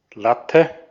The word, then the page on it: erection: /ɪˈɹɛkʃən/